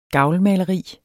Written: gavlmaleri

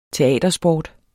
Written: teatersport

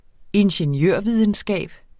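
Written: INGENIØRVIDENSKAB

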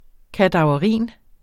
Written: kadaverin

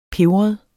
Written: pebret

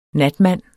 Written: natmand

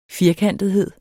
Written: firkantethed

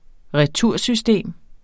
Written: retursystem

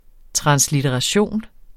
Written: translitteration

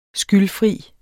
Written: skyldfri

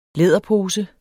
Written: læderpose

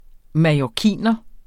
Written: mallorciner

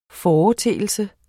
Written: FORETEELSES

